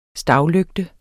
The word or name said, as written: stavlygte